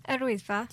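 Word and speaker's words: Yr Wyddfa: I'm not pronouncing the Welsh name again, how's it? – Edwisfa.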